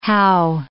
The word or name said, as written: hao